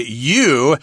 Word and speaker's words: ewe: Now, notice here, the word you is spelled E-W-E, not Y-O-U. You is ni, you know, anata, you know, you. But in any case here, this refers to female sheep, so we say you, and that's kind of a joke here, Sheep Facts You Won't Believe. So you, E-W-E, refers to a female sheep, and just in case you wanted to know, ram refers to the male version of a sheep.